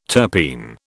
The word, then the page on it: terpene: British English